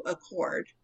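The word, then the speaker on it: Achord: Accord. I'm not sure if I'm pronouncing it right, but it's A-C-H-O-R-D.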